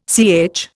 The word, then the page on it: CH: Indian